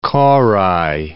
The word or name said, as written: korai